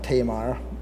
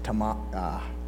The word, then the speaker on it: Tamar: I used to always pronounce that Tamar, and recently came to the understanding that it's pronounced Tamar. The emphasis is on the Mar, not Te, it's Tamar.